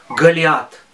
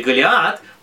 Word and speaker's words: Голиаф: Этот самый Голиаф, да, почему некоторые называют его Голиаф, а некоторые Голиат, потому что некоторые говорят на ашкеназисе и произносят «с», а некоторые говорят по-сефардски и произносят «т», да, «тов». «Тов» и «сов». Вы помните, что у нас эта буква, она читается по-разному в ашкеназском и в сефардском произношении.